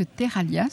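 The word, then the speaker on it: Terralha: Et vous m'avez expliqué que Terralia, ça voulait dire argile en occitan. – Oui, alors Terralia, ça s'écrit LH comme grouiller.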